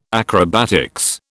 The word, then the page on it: acrobatics: British English